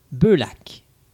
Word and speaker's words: Beullac: Beaulac. J'ai bien prononcé ça? – C'est très bien prononcé.